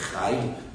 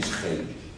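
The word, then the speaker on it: khayr: Arabic pronunciation will be khair. Persian is khair. Almost the same.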